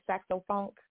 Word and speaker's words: Sax-O-Phunk: Did I say it right, Sax-O-Phunk? – Yes, it's going to be cool. – Yeah. – S-A-X dash, the letter O dash, and then P-H, P as in Paul, P-H-U-N-K.